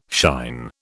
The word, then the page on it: shine: British English